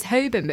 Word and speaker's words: Holborn: And it's Holborn, but people pronounce the L. Holborn. They say Holborn.